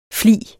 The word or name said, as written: flig